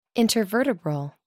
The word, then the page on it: intervertebral: American English